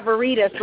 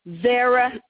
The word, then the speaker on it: Veritas: Okay, I've been saying your name wrong. I'm talking about Burritas, like Burritas. My mother would kill me. Oh, my mother would have a fit if she knew I pronounced you all's name wrong. Veritas.